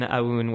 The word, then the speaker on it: Aoun: at Adrian Aoun, where Aoun is spelled A-O-U-N.